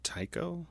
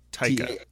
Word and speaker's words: Taika: I think it's Tycho. – Tycho. – Oh, it's Taika. – Taika, yeah. – Taika's probably closest.